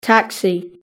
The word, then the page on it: taxi: British English